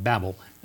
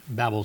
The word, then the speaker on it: Babel: Therefore is the name of it called Babel, that is confusion. That's why I like to pronounce it Babel. Babel sounds like what they would have heard, confusion.